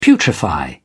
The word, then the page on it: putrefy: British English